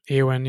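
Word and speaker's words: Éowyn: Eowyn? Is that how you say it? – Eowyn, yeah.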